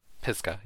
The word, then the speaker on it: Pisgah: I think it's Pisgah? – Pisgah, yeah.